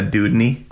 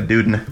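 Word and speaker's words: Dewdney: That whole book series by Anna Dudney. – Okay. – But she always says by Anna Duden.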